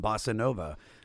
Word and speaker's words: bossa nova: bossa nova, um, or how do you say it? – Bossa Nova.